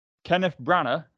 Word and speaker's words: Kenneth Branagh: We have Kenneth Branagh, I assume that's how that's pronounced. – Yeah.